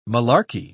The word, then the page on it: malarky: British English